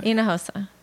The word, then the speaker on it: Hinojosa: Okay, so simply it's four syllables. H is silent, J is an H, so it's Hinojosa.